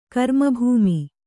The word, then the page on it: ಕರ್ಮಭೂಮಿ: karmabhūmi